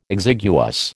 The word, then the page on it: exiguous: American English